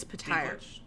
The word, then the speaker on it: Petyr: Pronounces this is not Peter Baelish. It's Petyr.